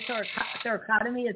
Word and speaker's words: thoracotomy: I'm telling you, I had a thoracotomy.